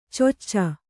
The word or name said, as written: ಚೊಚ್ಚ